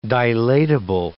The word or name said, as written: dilatable